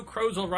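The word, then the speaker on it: Krozel: Tuesday, December 8th by Joe Crozel rhymes with puzzle.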